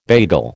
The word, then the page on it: bagel: American English